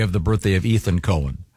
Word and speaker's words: Cohen: Leonard Cohen. – Cohen. – This that's confusing because no, not when it's spelled C-O-H-E-N.